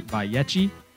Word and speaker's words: Vayechi: Here in studio with Pastor Nick Plummer, and we are discussing the Torah portion, Vayechi, which here in Florida we say Vayechi, which is the correct Hebrew pronunciation, of course.